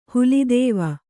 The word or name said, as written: ಹುಲಿದೇವ